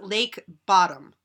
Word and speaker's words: Bodom: I believe it is pronounced, it looks like Bodom, B-O-D-O-M. I believe it is Lake Bottom.